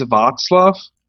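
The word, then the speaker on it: Václav: No, actually, it's Vaclav. So you don't pronounce it as K, but as a C, as in word circle.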